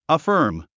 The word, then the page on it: affirm: əfə’ː r m; アファーム